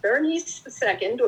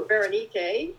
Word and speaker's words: Berenike: um, Berenice II, or Berenike, if we want to use a Greek pronunciation, was a queen of Egypt.